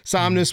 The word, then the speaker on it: Somnus: So moving from there, we have another new character named, pronounced this wrong a million times already, Somnus. Somnus? – That's what I'm going with. – N-U-S, Somnus. Somnus.